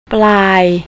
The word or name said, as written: plai